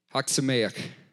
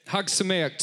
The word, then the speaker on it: Chag Sameach: I simply said from our Seder at church, and then how do you say this? C-H-A-G space S-A-M-E-A-C-H. Haksameach.